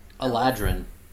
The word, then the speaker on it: Eladrin: I'm technically a Eldrian. – Eladrin. – Eladrian, my bad.